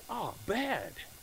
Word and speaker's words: abad: That word worship, in the original, it actually is abad. And I believe it's actually pronounced abad. He said, ah, bad. But don't say it like that, like it's bad, but say, ah, bad.